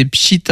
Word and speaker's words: Pschit: Vous pouvez suivre, c'est Pschit Impro, P-S-C-H-I-T. En fait, c'est le bruit d'une étincelle. C'est l'onomatopée de Pschit.